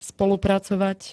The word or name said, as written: spolupracovať